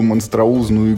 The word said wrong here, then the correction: монструозно: игру. Монструозно, правильно говорить.